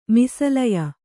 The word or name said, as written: ಮಿಸಲಯ